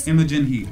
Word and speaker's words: Imogen Heap: The crazy thing about that to me is that song by Imogen Heap, is it? Did you say? – Imogen Heap.